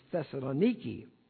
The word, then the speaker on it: Thessaloniki: We visited a Greek Orthodox church in what the Greeks pronounce as Thessaloniki. We pronounce it Thessaloniki.